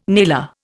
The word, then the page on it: nailer: Indian